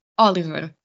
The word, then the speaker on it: Oliver: Oliver. – Oliver. Okay. – Uh-huh. Very similar, just the R is a little bit different. Instead of ver, is ver.